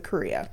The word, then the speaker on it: chorea: But, so as of 2011, doctors are pretty sure that Elizabeth most likely had a disease called chorea. Which is spelled C-H-O-R-E-A. – Okay. – But it's pronounced like Korea.